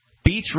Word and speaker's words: Beach: Beach Radio or Bitch Radio? – Bitch. – Spell it. – B-E-A-C-H. Bitch Radio. – B-E-A-C-H.